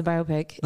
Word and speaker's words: biopic: Yeah, it's a biopic. – Not biopic? – No, it's a bio, like biopic. – That makes a lot more sense.